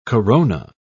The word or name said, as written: corona